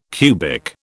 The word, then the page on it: cubic: British English